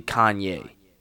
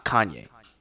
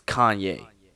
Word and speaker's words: Kanye: But it makes even more sense that when you understand that the emphasis his parents put on his name is literally Kanye. It's not Kanye. It's Kanye. That's how you pronounce his name.